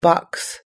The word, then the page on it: box: American English